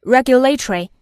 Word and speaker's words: regulatory: regulatory 美式发音 regulatory